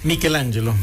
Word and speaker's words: Michelangelo: Michelangelo, ¿cómo se pronuncia? – Michelangelo. – Michelangelo. Sí.